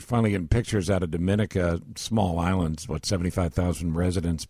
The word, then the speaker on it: Dominica: Nobody's ever heard of Dominica, is how it's pronounced.